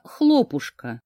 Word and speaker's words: хлопушка: На одной из лесных полянок, что вблизи небольшого городка, появился хлопушка. Да-да, ударение на букве О.